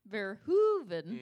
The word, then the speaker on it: Verhoeven: And, of course, this movie is directed by Paul Verhoeven. Mm.